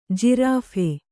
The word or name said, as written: ಜಿರಾಫೆ